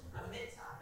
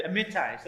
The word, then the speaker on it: Amittai: It says, the word of the Lord came to Jonah, son of, how do we say it? – Amittai. – There you go. She was working on it. I don't know. It says, Amittai.